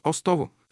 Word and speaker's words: Остово: И сега все още ще чуете старите хора от Устово и околията му да казват Остово с първа гласна О и ударение върху втората гласна О, а не Устово, както се произнася сега името на това село.